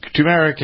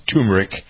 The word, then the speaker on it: turmeric: It's turmeric, and some people pronounce it turmeric.